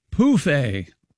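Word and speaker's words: pouf: use the French word pronounced pouffet for an ottoman, phonetically spelled P-O-O-F hyphen A.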